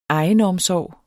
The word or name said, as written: egenomsorg